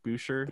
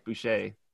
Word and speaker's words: Boucher: I've been seeing a lot of hype for Chris, how do you say his last name? The B, Boucher? – Boucher. – Boucher. – It's French. He's Canadian.